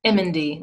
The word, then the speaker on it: Emendy: Emendy, like you're saying the Emendy. – letters MND.